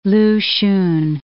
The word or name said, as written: Lu Hsun